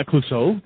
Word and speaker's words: Clouseau: I totally butchered that French guy's Clouseau. – Name. It's terrible. – Yeah. – Yeah, Clouseau, there you go.